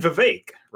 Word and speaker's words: Vivek: And Vivek, rhymes with cake, Ramaswamy.